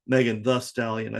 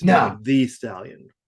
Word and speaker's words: Megan Thee Stallion: Like it's not Megan the Stallion. I think it's Megan Thee Stallion. Because right. I think there's actually two E's in the.